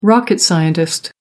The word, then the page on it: rocket scientist: American English